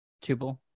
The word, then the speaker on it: Tuple: Tuple. – Tuple. – I think in Python, it's called a tuple, but the company is Tuple. – That's so funny. In TypeScript, we call it tuple, and there's a JavaScript proposal for a completely different thing called, or somewhat different thing called a tuple in JavaScript.